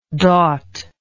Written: daut

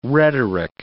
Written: rhetoric

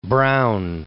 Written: Braun

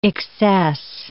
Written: excess

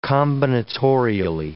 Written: combinatorially

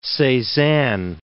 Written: Cezanne

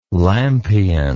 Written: lampion

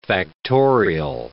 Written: factorial

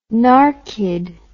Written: narkied

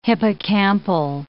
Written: hippocampal

